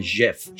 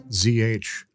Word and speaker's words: GIF: I go a different route. I go with like the European G. I pronounce them GIF. – GIF. Oh yeah. – Because it annoys everyone. – Yeah. ZHIF.